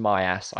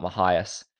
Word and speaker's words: Mahias: It is actually pronounced Myas, I found out this weekend, not Mahias. So I felt like a bit of an ass for saying my ass for for most of the broadcast, but that is how you say his name.